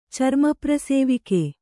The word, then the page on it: ಚರ್ಮಪ್ರಸೇವಿಕೆ: carma prasēvike